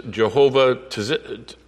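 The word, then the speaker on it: Jehovah Tsidkenu: It's like a TZ. And so it is Jehovah Tzitkenu.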